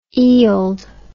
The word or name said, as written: eild